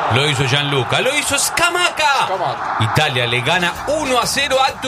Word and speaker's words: Scamacca: Scamacca. – Qué bien que lo dice el fiscal. – Es como se pronuncia, no es que yo lo digo bien o mal.